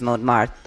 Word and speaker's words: Montmartre: And then our Closing Night film, which is Montmartre. I can't pronounce it because I'm not French. – I think that was well done. – Was that well done? Okay, good.